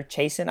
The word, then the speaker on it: Chaisson: Is it Chaisson or Chaisson? I forgot how you say his name. – Chaisson. – But Chaisson, okay.